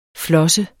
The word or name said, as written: flosse